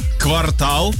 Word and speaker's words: квартал: Слово квартал так. – Произносится как квартал как в любом случае. – Не квартал. – Да, квартал, а квартал, он всегда квартал.